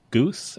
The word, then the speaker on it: Guth: It's like Coach Guth. Am I saying that right? Guth? – That's right.